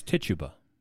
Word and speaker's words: Tituba: Titabu? – It's Tituba.